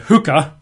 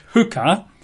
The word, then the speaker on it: chuqqah: Well, statutes in Hebrew is a word which I believe is pronounced as a hookah, not a lady of ill repute that you find in nightclubs. Yeah, hookah is spelled in English letters C-H-U-Q-Q-A-H. That's how it's normally written. C-H-U-Q-Q-A-H, hookah.